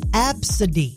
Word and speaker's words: Abcde: So the first name is A-B-C-D-E. It's actually spelled, all caps, A-B-C-D-E. Pronounced Absidy.